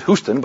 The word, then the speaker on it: Houston: He pronounces it Houston, but I find that hard to say.